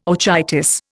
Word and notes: orchitis: Indian

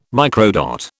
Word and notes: microdot: British English

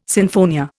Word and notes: sinfonia: Indian

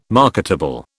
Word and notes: marketable: British English

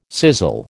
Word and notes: sizzle: American English

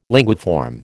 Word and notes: linguiform: American English

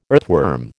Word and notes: earthworm: American English

